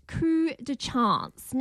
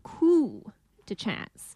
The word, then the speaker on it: Coup de Chance: Let's talk about Coup de Chance. Now it's actually spelt, if you see it, C-O-U-P-E, coup, but it's pronounced coup de chance. It is a French film.